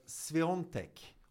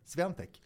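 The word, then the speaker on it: Świątek: Alltså att polackerna uttalar just det här. När det stavas sån här då Swiatek, som O-N.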